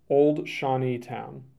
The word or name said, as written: Old Shawneetown